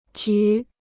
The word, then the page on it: 局: jú